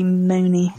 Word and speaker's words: Muhney: It's M U H N E Y. I don't know quite how to pronounce it. – Must be Mooney.